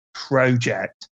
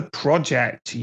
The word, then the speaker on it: project: I just hate the way Australians and Americans say project. It's called a project, you motherfuckers. – Project. – Stop bastardizing our language. – Project. – It's a fucking project.